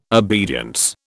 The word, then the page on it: obedience: British English